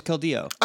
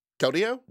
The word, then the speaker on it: Keldeo: Or no, it was Keldeo. – Oh, yeah, it was Keldeon. Keldeo? – No, just Keldeo. – Keldeo, no N. – Okay. – Keldeon is my favorite Eeveelution. – No N, accent on the O.